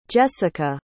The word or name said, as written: Jessika